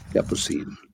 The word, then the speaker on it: Capucine: Capucine.